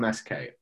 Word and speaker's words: Omsk: on August 20th, 2020, Alexander Navalny, he was hospitalized in Omsk, or I'm probably pronouncing it wrong. OMSK, Omsk. It's a Siberian city, northeast Russia.